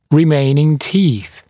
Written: remaining teeth

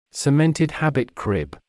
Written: cemented habit crib

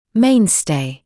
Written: mainstay

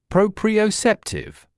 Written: proprioceptive

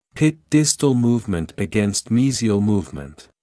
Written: pit distal movement against mesial movement

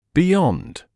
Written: beyond

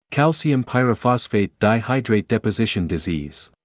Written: calcium pyrophosphate dihydrate deposition disease

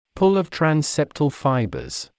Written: pull of transseptal fibers

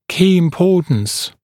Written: key importance

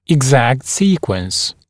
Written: exact sequence

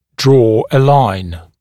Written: draw a line

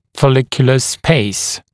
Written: follicular space

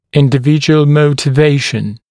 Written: individual motivation

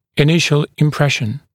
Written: initial impression

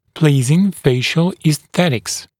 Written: pleasing facial esthetics

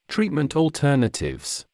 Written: treatment alternatives

